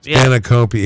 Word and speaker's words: spanakopita: Spanakopita? – Spanakopita, yes.